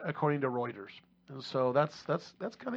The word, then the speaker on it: Reuters: Reuters. – How many times do I say that wrong? – You say that wrong every time. – Just a couple of hundred, yeah. – Routers.